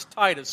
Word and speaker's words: Titus: And it's important you have a long I. It's Titus. So when you're at home for lunch, they're like, what did you talk about at church today? Titus is not correct. Titus is what it's called.